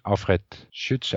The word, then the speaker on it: Alfred Schütz: Then Hayek and Alfred Schütz, am I saying that correctly? – Yes.